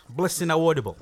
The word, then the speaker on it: Blessing Awodibu: Is that fair enough to pronunciation there, Blessing? – Yeah, you're pretty close. Blessing Awodibu.